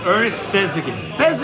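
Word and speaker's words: Ernest Thesiger: Ernest Thesigan. – Ernest Thesigan.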